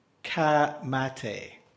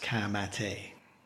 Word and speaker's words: Ka Mate: So we've got Te Rauparaha, 1905, Kapa o Pango, and finally, Ka Mate, which is spelt like a Ford Ka, and the second word is spelt like the English word mate, but it's pronounced Ka Mate.